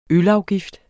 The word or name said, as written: ISNET